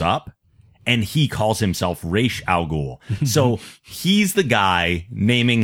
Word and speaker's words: Ra's al Ghul: He knows how to say it. He says it properly. And everyone continues to say Ra's al Ghul. I'm like, God damn it. You heard him say it himself. It's Ra's al Ghul.